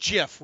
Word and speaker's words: GIF: And it's pronounced GIF. We finally got that. The guy who invented that took care of that.